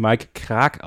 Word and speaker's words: Krack: Übrigens habe ich gehört, äh, Mike Krack wird Mike Krag ausgesprochen. Da hat uns ein luxemburgischer äh, Hörer Schrägstrich Zuschauer des Livestreams darauf hingewiesen, dass er Mike Krag ausgesprochen wird.